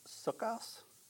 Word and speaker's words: Sukkos: So the Feast of Booths is also known as Sukkos. Am I saying that right? Sounds right.